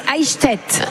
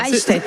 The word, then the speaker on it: Eichstätt: Alors la ville jumelée avec Montbrison en Allemagne, c'est Eichstätt. – Ah ok, très bien. Eichstätt.